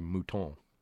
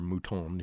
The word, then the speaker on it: Mouton: author Ray Mouton, or Mouton, if you want to pronounce it in American.